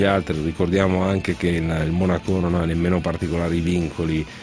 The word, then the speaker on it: Monaco: Allora, leggo sui siti di un'asta tra Monaco, con l'accento sulla O, quindi quelli del Principato, e Paris Saint-Germain per Cristiano Ronaldo, super offerta dal Principato, 23 milioni di euro.